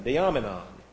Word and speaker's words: diamonion: D-I-A-M-O-N-I. Is there an A there? No. O-N-I-O-N. And let me get the accent right so that you won't come up and say, you put the wrong accent on there. D-I-A-M-O-N-I-O-N. Diamonon.